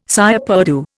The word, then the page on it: cire perdue: Indian